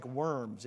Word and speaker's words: Worms: It looks like worms if you just read it out in English. But you would say Worms, okay? Because the W is pronounced like a V in German.